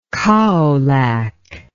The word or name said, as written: kaolack